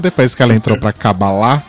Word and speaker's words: Kabbalah: Eu fiquei sabendo que a pronúncia correta é Kabbalah, não é Kabbalah.